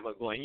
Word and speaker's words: McGloin: let alone a guy like McGloin. Is that the name? Am I saying that right? – Yeah, Matt McGloin.